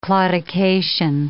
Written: claudication

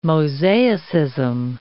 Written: mosaicism

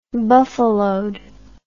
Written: buffaloed

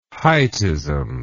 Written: heightism

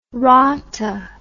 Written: rata

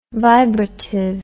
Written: vibrative

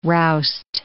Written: roust

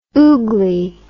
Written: oogley

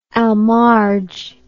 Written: al marj

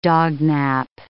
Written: dognap

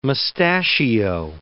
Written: mustachio

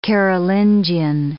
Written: Carolingian

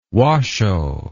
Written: washo

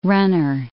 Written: runner

